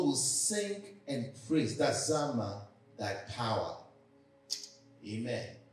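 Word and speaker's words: Zamar: Oh, say it like you mean it. Zama. That's Z-A-M-A-R.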